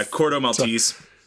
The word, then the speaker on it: Corto Maltese: So we follow our A team into Corto Maltese. Am I saying that right? I feel like I've said it differently every time. – Yeah, Corto Maltese.